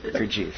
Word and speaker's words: Gurdjieff: Gurdjieff.